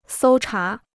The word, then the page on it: 搜查: sōu chá